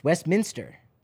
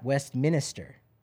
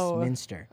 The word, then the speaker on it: Westminster: It's Westminster, not Westminster. It's not Minister. – Oh, no. – It's Westminster.